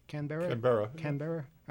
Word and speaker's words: Canberra: We have a great story that comes out of Australia from the Canberra Times. Am I pronouncing that right? – Canberra.